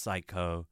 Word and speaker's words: Sycco: I just realized I've been pronouncing their name wrong. It's pronounced Psycho, but it's written as S-Y-C-C-O, as a reference to psychedelic pop sound. And that's sort of where her artistry is born from as well. So just clarifying that the artist's name is pronounced Psycho, but spelled S-Y-C-C-O.